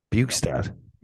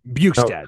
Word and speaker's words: Bjugstad: Not Bjorkstrand. Bjugstad. – Bjugstad, oh. There we go.